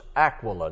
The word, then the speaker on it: Aquila: He said, when the word has one L, it is pronounced Aquila.